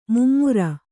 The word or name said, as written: ಮೈಮರೆ